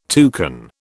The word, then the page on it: toucan: British English